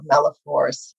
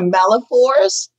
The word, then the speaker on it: malaphors: I don't know whether you've heard of Malifors, but I'm apparently quite good at it. – Say it again. What is the word? – A Malifors. – Spell it. Can you spell it for me? – Yes. M-A-L-A-P-H-O-R-S.